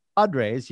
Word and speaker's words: Padres: Because Padres, you had to learn how to trill your R's. Did I say that correctly? – Yeah, I roll them.